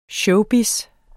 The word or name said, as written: showbiz